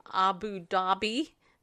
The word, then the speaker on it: Abu Dhabi: Abu Dhabi, my word of the day.